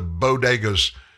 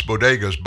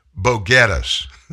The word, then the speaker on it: bodegas: When invoking the Bronx bodegas, Biden mispronounced the word for the small grocery stores, calling them as boguetas.